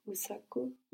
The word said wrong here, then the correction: usiku: Usiku. – Usiku. – Good.